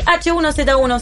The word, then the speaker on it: H1Z1: Pero el H1Z1, ¿se dice así? – Sí, sí, sí, señor.